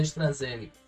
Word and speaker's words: AstraZeneca: A pronúncia é AstraZeneca.